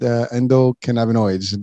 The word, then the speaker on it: endocannabinoids: But so again, that's all I can say, but I think I got the word endocannabinoids.